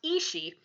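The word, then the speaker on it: aos sí: So, this is another one that I had to spell phonetically because it's spelled A-O-S-S-I, but it's apparently pronounced Ishii.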